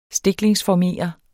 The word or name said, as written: stiklingsformere